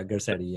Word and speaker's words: Garcetti: Yeah, I would definitely say Newsom, and then is Carchetti, or Garcetti, you pronounce it, Garcetti. – Yeah, Garcetti, yeah.